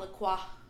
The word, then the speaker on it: La Croix: It's actually called lacroix. – And a croix. – No. You're saying it how it's spelled, but it's called La Croix. – I know. – Because it's French.